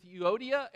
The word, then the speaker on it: Euodia: I plead with Euodia and I plead with Syntyche. That's how you say it.